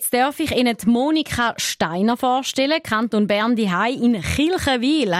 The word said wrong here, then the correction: Kriechenwil: Habe ich das richtig gesagt? – Ja, Kriechenwil.